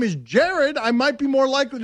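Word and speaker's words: Jarrod: People are writing in, it's pronounced Jared.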